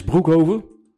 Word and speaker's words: Broekhoven: Toen ging jij van KS Broekhoven. Zeg ik dat goed? – Je zegt het goed, dat is lang geleden.